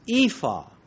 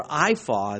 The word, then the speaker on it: ephah: Basket in the Hebrew, translated basket in the New King James, is ephah, or iphah, as some could pronounce it.